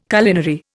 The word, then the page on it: culinary: Indian